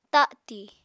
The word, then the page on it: tati: apatani